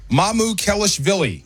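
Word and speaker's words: Mamukelishvili: Just break it down by syllable. So, Mamu. – Mamu. – Kelish. – Kelish. – Vili. – Mamu Kelishvili. – Mamu Kelishvili.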